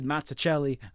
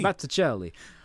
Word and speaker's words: Mazzucchelli: Wasn't Daredevil season three a straight up adaptation of the Frank Miller, David Mazzucchelli? I, I always gotta say it like with Italian hand. I am Italian, well, so I can get away with so it. So is he Mazzucchelli?